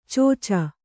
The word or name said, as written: ಚೋಚ